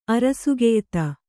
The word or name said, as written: ಅರಸುಗೆಯ್ತ